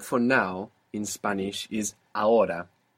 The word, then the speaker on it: ahora: This is spelled A-H-O-R-A. A-H-O-R-A. Ahora. – Ahora. – Good. – Is the H silent in every... – Yeah, unless it's C-H, in which case it's like English, ch.